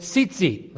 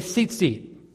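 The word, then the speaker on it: tzitzit (ציצית): The Hebrew word for wings here is the word tzitzit. Let me hear you say tzitzit. Tzitzit is spelled T-Z-Z-T, T-Z-Z-T, tzitzit.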